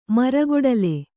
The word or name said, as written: ಮರಗೊಡಲೆ